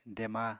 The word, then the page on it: dema: apatani